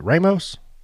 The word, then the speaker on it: Ramos: is it Ramos? – Right.